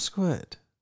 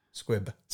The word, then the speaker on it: squib: Damp squid? – Yeah. – What are you on about? – I'm saying it incorrectly for a gag. – It's a damp squib.